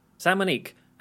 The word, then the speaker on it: San Monique: San Monique, an island in the Caribbean, it says.